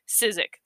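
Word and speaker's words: Szyc: Which is Sizek? Sizek, that's how you say it.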